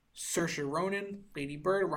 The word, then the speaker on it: Saoirse: Yeah, it is Saoirse Ronan.